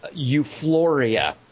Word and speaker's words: Euphloria: It's called Euphloria. rhymes with euphoria, but floria. So it's got a little sort of a plant theme going with trees. So it's like euphoria, but with trees, euphoria.